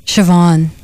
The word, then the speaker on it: Siobhan: Siobhan. – Oh, why can't they spell it like it sounds? S-H-I-V-O-N. – It's Siobhan. Irish. – Oh, okay. It's one of those names like Sean.